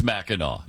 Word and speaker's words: Mackinac: Mackinac Island? – Oh, no. Mackinac Island. – Mackinac Island. – First of all, It's spelled, why do they spell it Mackinac when it's Mackinac? It because doesn't make any sense.